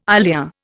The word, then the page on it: ilia: Indian